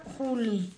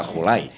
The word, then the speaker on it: Jolie: Angelina Juli. – La Juli, Angelina la Juli. La Julay. Angelina Juli. – Oh, es que parece una J.